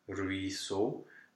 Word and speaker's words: ruisseau: But then at the end of this word, we have E-A-U, three letters. – Mm-hmm. With an X, with a silent X. – With an X, yes. So four letters, three vowels, one consonant. Let's just eliminate the consonant. The consonant is just silent, just because it's silent. It's fun. X, don't pronounce it. Then we're left with these three vowels. And what do you do with that? E-A-U is a standard spelling. It's just a closed O, ruisseau. And every time you see an E-A-U, you will have to go automatically to a closed O.